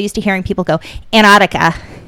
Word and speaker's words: Antarctica: Well, it's funny because it's spelled A-N-T-A-R-T-I-C-A, Antarctica. – Right. – And for the longest time, I would hear a lot of people, maybe it's a New England thing, but they just say Antarctica, like Antarctica. – Yeah. – And so they don't pronounce that T.